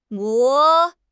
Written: guo2

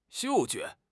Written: 嗅觉

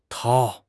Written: 掏

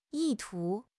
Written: 意图

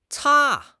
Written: ca1

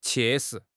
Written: 切实

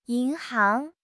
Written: yin2 hang2

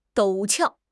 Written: 陡峭

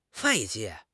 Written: 外界

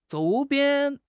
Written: 周边

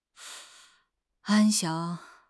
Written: 安详

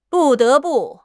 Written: bu4 de2 bu4

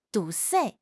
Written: 堵塞

